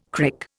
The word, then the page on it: crick: Indian